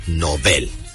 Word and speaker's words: Nobel: Ambas palabras son agudas, por lo tanto, su pronunciación adecuada es Nobel.